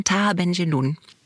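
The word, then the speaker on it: Tahar Ben Jelloun: Und das erste Buch, das du vorstellen wolltest, ist Sohn ihres Vaters von Taha Benjelloun. – Genau. – Ich hoffe, ich habe es richtig ausgesprochen. – Ja, total. Ja.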